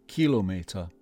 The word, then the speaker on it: Kilometer: The stress is on Kilo. Kilometer.